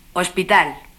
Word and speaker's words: hospital: Como sabes, en español la H no se pronuncia nunca. Escucha y repite las palabras siguientes. Hija. Hospital.